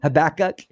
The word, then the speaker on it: Habakkuk: And here in a little bit, I think maybe tomorrow or the next day, we're going to go over Habakkuk. Am I saying that correctly? – Habakkuk.